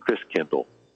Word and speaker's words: Christkindl: And the German pronunciation of Christ child is Christkindl.